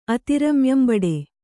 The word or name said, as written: ಅತಿರಮ್ಯಂಬಡೆ